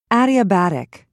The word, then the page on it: adiabatic: British English